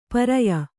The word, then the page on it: ಪರ್ಯ: Kannada